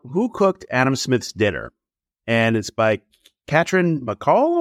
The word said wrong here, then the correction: Katrine Marçal: or how do you pronounce her name? – I think it's Katrin Marcel.